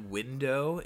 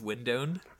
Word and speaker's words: window: It's like, I wonder what Willett's going to say when he realizes that window is not pronounced with an N on the end because he still says windown.